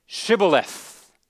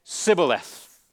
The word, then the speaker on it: Shibboleth: Those from the east, the Gileadites, pronounced it Shibboleth, while those from the west, the Ephraimites, said Sibboleth.